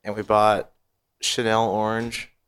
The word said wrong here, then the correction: Channel Orange: Channel Orange. I always say it wrong.